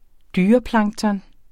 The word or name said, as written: dyreplankton